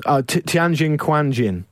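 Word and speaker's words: Tianjin Quanjian: Tianjin Quanjin.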